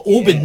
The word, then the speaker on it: Oban: One of the easy ones, but it's always mispronounced by mostly people from North America is Oban. We call it Oban, yeah.